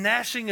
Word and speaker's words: gnashing: where there will be weeping and gnashing of teeth. Why does that word have a G at the beginning? I have no idea, all right? Then the gnashing of teeth, G-N, that's how it's spelled, by the way.